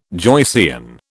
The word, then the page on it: Joycean: British English